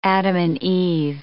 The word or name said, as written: adam-and-eve